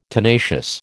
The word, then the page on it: tenacious: American English